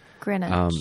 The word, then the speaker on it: Greenwich: Like, there's no reason for it to be Greenwich? Greenwich? I don't know how to say it. – Greenwich.